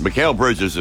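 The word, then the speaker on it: Mikal Bridges: Listen, Mikhail Bridges is kind of tough because M-I-K-A-L does not tell the brain it's Mikhail.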